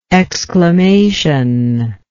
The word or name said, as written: exclamational